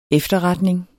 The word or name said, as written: efterretning